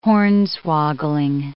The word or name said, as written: hornswoggling